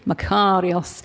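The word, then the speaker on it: Makarios (μακάριος): Am I saying it right? She's a Greek. She speaks fluent Greek. Oh, there she is. And I said, is it Makarios? Yes, Makarios.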